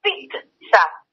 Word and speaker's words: pizza: Investigué en este, en este primer programa de esta columna, investigué sobre la pizza, porque se dice así, P-I-T-S-A, pizza, aunque se escriba P-I-Z-Z-A.